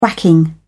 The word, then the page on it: whacking: British English